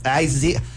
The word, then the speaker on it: Isaias: It's Isaias.